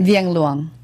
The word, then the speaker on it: Vienguang: Vienguang.